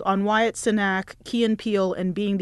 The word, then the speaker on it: Wyatt Cenac: We were talking earlier, and I'm always butcher his name, Wyatt Cenac.